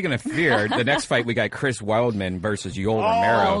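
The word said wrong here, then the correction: Weidman: You said his name wrong. – She will fight you. – I just. – Yoel? – No. – Wideman. – I don't care about Yoel Romero. You can say his name wrong. – Yeah. Wideman? Is that how you say it? – Yeah.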